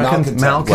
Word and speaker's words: malcontents: What is not is your interaction with almost uncontrollable reaction to the idiocy, that you engage in the trolls, misfits, and malencons. Am I saying that right? – Malcontents.